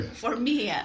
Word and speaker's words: Formia: Say your last name. – Formia. – Formia.